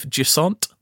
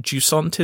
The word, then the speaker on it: Jusant: If you don't know what Jusant is, and that's spelled Jusant, I'm saying it like that because it sounds French. Well, it's developed by a French company.